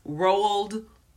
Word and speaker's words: Roald: I have come to find out that Roald Dahl, who is the author of that book, I always feel like I'm saying it wrong because it's like Ronald without the N, and that gets on my nerves.